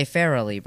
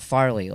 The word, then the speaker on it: Farrelly: And in my head, whenever they say it, I thought Farley, like Chris Farley. – Like Chris Farley, but there's more syllables in it.